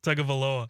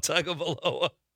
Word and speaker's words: Tagovailoa: Tagovailoa. – I don't think Chad said it right. – Tagovailoa. – Tagovailoa.